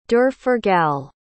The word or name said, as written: derforgal